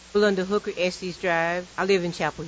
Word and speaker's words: Estes: We hit the streets to find out how you say it. Estes Drive or Estes Drive? – My name's Rebecca Christenberry, and I live in Chapel Hill. I say Estes Drive. – Ryan Lynch, I live in Chapel Hill, and it's pronounced Estes.